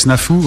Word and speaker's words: Snafu: Bah, on va les écouter ensemble et puis juste derrière il y aura un choix From Matt avec Snafu, on prononce comme ça.